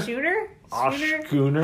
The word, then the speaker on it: schooner: Am I saying? – Schooner? – How do you say the name? – Schooner. – Yeah. Schooner. – You're fine.